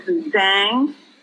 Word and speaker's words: Zhang: My first name is Hong, H-O-N-G, like Hong Kong. And last name is Zhang, Z-H-A-N-G, Zhang.